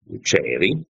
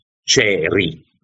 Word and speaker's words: Chery: che si chiama Chery, scritto Chery, ma siccome i cinesi non pronunciano la R, suona molto, molto, molto simile a Chevy.